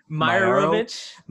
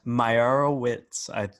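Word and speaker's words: Meyerowitz: And when you look at, when you reference, let's say, Joel Meyerowicz, you know, when you reference him, I butchered the heck out of that last name. – Meyerowicz. – Meyerowicz? – Yeah, I think that's it.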